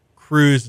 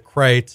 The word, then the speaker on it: Kreuz: If you pronounce K-R-E-U-Z and Lockhart as Cruz instead of Kreitz, it's like, you know, this is what separates the barbecue devotees from the Johnny Come Latelys, I think.